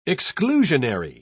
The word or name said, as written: exclusionary